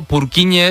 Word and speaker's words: Purkinje: Es que se escribe, en español sería Purkinje. – Purkinje. – Pero Purkinje es en checo, que es porque es el apellido del descubridor.